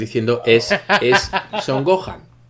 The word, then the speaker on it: Gohan: Entonces, pues Gohan sería Gohan, ¿no? Vamos a decir, porque la H es muda, no se pronuncia.